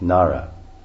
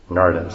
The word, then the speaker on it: Nada: Nada, or Nada is actually, of course, as I'm saying in English, and we see it says Na, long A, Na, and then D, it's a rolled D, with a da, and then long A. Actually, this word, we say Nada, but basically it's Nara. And it's actually the correct pronunciation, because it's a D with a dot under it, which means rolled R. Narda, something like this.